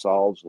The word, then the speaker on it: salve: Is that how you say it? I say solve. – Solves. It's like you're going to solve a problem. So it's a salve. Salve.